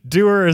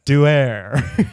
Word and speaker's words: Duer: Doer is spelled D-U-E-R. – That's like... – Doer.